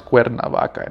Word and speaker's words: Cuernavaca: And Cuernavaca, it's pronounced Cuernavaca in Spanish, right? – Yeah.